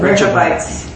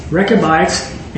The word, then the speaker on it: Rechabites: Rechabites. – Rechabites, and speak with them, and bring them into the house of Yahweh, into one of the chambers, and give them what? – Wine.